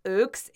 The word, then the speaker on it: OAUX: Okay, so Ooks is, I often say it's the British pronunciation of OAUX because, hi, Imogen Levy, she started calling it Ooks, and then I think everybody in the UK basically calls it Ooks.